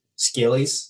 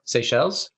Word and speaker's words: Seychelles: Rwanda, St. Barth's, St. Lucia, St. Martin, St. Vincent, and the Grenadines, Scillies, which is right off the coast of Africa. Right? – Scillies. – How do you pronounce that? How do you say that? – Seychelles.